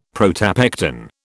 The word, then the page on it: protopectin: British English